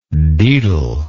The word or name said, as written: dedal